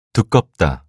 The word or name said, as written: 두껍다